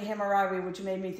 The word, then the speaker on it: Hammurabi: I mean, and cool things like um, Hammy Robbie. No, I got that name wrong. – Right, I know who you mean though. Go ahead. With the boats. – Mm-hmm. – Was that right? – Ham- I, I always heard it Hammurabi, but go ahead. I could be mispronouncing that too, though.